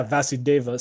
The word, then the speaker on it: Vasudeva: I think the main theme is it shows the importance of listening just like how the ferryman va, va, va how do you say it? Vasu Vasu, Vasudeva? – Yeah, Vasudeva.